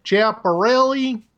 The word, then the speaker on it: Schiaparelli: I think I got that right, or being married to an Italian woman as I am, I will just excuse it by saying you use the American pronunciation. – Yes, I apologize for any offense I caused. Schiaparelli? – Yeah, that's a big improvement.